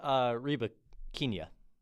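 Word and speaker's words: Rybakina: And she's facing Reba Kiña. Yes. Yes, that's how you say her name.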